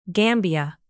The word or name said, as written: gambia